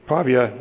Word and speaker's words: Pavia: is that how you pronounce? – Pavia, in Italy, under the mentorship of Professor Antonio Toroni.